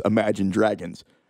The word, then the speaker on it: Imagine Dragons: And they go, Do you want to go see a band called Imagine Dragons? Obviously, now I realize that I put the emphasis on the wrong syllable and that it's Imagine Dragons.